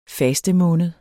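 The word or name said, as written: fastemåned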